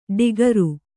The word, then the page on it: ಡಿಗರು: ḍigaru